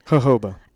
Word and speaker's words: jojoba: I ran out of jojoba oil. I think I'm saying that wrong. How do you say that, honey? – Jojoba.